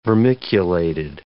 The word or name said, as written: vermiculated